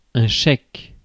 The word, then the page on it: chèque: /ʃɛk/